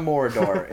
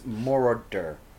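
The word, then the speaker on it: Moroder: It's not Morador, it's Morador, which is his last name.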